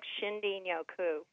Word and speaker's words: Shinrin-yoku: It's spelled like Shinrin-Yoku, but it's pronounced, I understand, a little bit more like Shin-Din-Yoku.